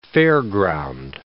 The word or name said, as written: fairground